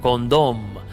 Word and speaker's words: Condom: Un lugar con apenas 7.000 habitantes que lleva por nombre Condom, con M al final, pero cuya pronunciación pues fonéticamente nos refiere a eh, ese anticonceptivo que, ojo, ellos han sabido adaptar muy bien el nombre al turismo creando precisamente el Museo del Condón.